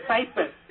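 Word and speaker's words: Peyer: It's P-E-Y-E-R. P-E-Y-E-R. Yeah, paper. You pronounce it paper. Like in paper in English.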